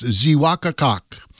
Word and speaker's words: Xeewakacak: Franks Hill, or in Native American, it's pronounced Zewakakak. That's spelled X-E-E-W-A-K-A-C-A-K.